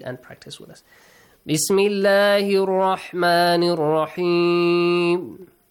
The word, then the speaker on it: الله: وَكَانَ اللَّهُ This is the example of the word Allah pronounced heavily.